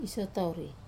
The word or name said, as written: sotauri